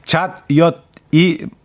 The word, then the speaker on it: 잣엿: 어, 나지영 씨, 전 자젓, 자젓, 저 발음이 잣엿이 맛있던데. 어, 이렇게 똑바로 읽어야지.